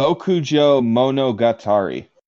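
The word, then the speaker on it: Bokujō Monogatari: Bokujo Monogatari.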